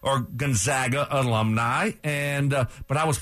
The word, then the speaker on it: Gonzaga: Yes, it's Gonzaga.